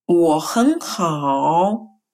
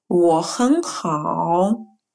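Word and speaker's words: Wo hen hao (我很好): Все первые слоги меняются на второй тон, и только последний слог читается третьим тоном. Например, у меня все хорошо. Wo hen hao. То есть слова я wo и слово очень хэн мы произносим вторым тоном, и только последнее слово хао хороший мы произносим третьим тоном.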